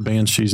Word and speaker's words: banshee: They were pronounced, I'm trying to remember, they were spelled like Bean City, but knowing that the correct pronunciation is banshee makes a lot more sense now as well.